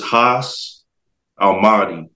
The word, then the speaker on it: Haas Almahdi: Yeah, so it's Haas Almahdi.